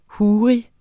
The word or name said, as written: huri